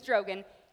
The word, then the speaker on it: Drogon: His name is Drogon.